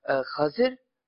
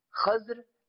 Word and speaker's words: Khizr: And the word Khizr can be pronounced in three different ways. Khazir, Khazr, and Khizr.